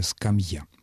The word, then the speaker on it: скамья: А вот что касается скамьи, по всей видимости, ведущий той передачи, которую вы слушали, оговорился, конечно, правильное ударение «скамья».